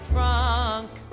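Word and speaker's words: Franck: By Cesar Frank. – Pronounce it Frank.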